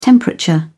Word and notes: temperature: British English

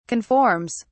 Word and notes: conforms: British English

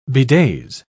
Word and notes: bedaze: British English

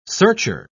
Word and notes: searcher: British English

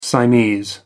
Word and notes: siamese: American English

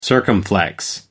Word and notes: circumflex: American English

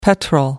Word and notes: petrel: American English

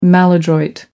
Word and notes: maladroit: American English

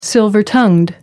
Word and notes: silver-tongued: American English

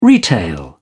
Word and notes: retail: British English

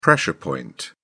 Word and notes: pressure point: British English

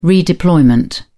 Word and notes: redeployment: British English

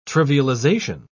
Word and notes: trivialisation: British English

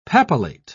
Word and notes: papillate: British English

